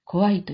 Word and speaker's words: 強い: 怖いとは、強いと書いて、怖いと読みますが、硬い状態のことです。